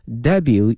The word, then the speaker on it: debut: 那么呢今年的2月1日呢他的获奖作品呢在纽约首次亮相。On February 1st, her award-winning creations, 获奖的英文叫 award 这个词 ,A-W-A-R-D, 奖项 ,winning, 获奖哈 ,award-winning creation made its debut in New York, 首次亮相哈，英文有一个专门词叫 debut, D-E-B-U-T, 但同学们注意了，这个后面这个 T 呢是不发音的，是 make something 或 someone'sDebut 表示首次亮相、首次登场的意思哈。接下来这个词组 make debut，